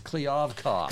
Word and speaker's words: Kliavkoff: It's Kliavkoff. – Kliavkoff. – George Kliavkoff.